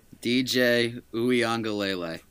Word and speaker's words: DJ Uiagalelei: DJ Uiagalelei.